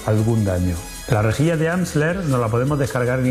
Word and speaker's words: Amsler: De Amsler, sí, así lo he dicho bien.